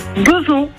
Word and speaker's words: Beuvron: Alors vous êtes à Beuvron, on prononce bien comme ça, hein, Beuvron ? – Beuvron, oui.